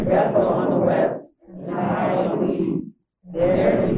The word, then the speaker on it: Ai: He moved to Ai, as it's pronounced.